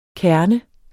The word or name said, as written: kerne